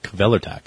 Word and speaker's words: Kvelertak: She went over there, and how do you say your band's name? And he's like, "Kvelertak."